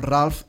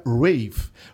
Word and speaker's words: Ralph: He hagut de mirar realment, perquè sempre estem amb Ralph, Ralph, Ralph. Realment es pronuncia Rave.